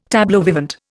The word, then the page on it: tableau vivant: Indian